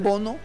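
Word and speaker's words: Bounou: Es que lo escriben Bonu, pero lo pronuncian Bono.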